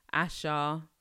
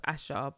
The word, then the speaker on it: Asa: I've also been rinsing out Asha. I think it's pronounced Asha, but it's spelled Asa, A-S-A.